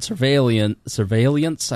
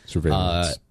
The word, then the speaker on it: surveillance: ending government surveillance, I always say that wrong. – Surveillance.